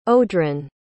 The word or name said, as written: odran